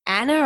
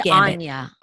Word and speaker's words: Anya: I think I asked you this another time, but do you go with Anna or Anya? How do you say her name? – I say Anya.